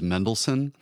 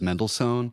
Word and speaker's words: Mendelssohn: I know that the correct pronunciation is Mendelssohn, but everybody else on staff says Mendelssohn.